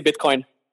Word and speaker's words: Bitcoin: Do you say Bitcoin or Bitcoin? That's actually a question that I've had for a while. – We just say Bitcoin. It's pronounced pretty much the same exactly, actually.